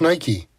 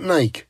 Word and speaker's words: Nike: And by the way, the name is pronounced Nike, not Nike.